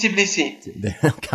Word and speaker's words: Tbilisi: The city is Tbilisi.